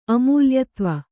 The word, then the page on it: ಅಮೂಲ್ಯತ್ವ: kannada